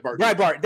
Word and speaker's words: Breitbart: I mean, I'm like, how do you say that name? – Breitbart. – Thank you. Breitbart, yes, I got you.